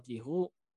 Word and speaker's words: Giroux: Nah, Sif, ini apakah Giroux atau Giroux? Ini Giroux ya. Bacanya. – Ya udah Giroux lah.